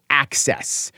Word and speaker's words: AXS: And I found out afterwards that it's pronounced Access. They'd like for me to pronounce it Access. I'm not pronouncing it Access. Up yours, Access. And by the way, up yours is spelled UPYRS. So Axis,